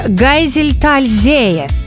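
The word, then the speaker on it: Geiseltalsee: Гайзельтальзее. Там двойное ударение.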